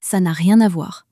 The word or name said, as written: ca n a rien a voir